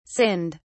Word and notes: sinned: British English

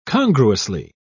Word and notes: congruously: British English

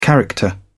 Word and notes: character: British English

